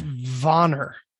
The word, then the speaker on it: Vanir: I think that's pronounced Vanner.